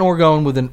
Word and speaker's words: orgone: And we're saying orgone with a hard O, like a long O. I think that's correct, even though it's orgone, but I don't want to say orgone because it sounds like the state. I think it's orgone.